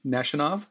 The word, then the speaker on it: Nishanov: Nishanov? – Yes, Gore.